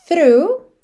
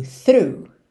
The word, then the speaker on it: through: Essa palavra through, que é T-H-R-O-U-G-H, e rough, que é R-O-U-G-H. Mas as pronúncias são muito simples. No through, a gente tem o GH completamente mudo. Through, through.